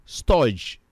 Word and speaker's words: storge: Another form of love is called storge. I will spell it for you. It's S-T-O-R-G-E.